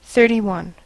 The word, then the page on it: thirty-one: American English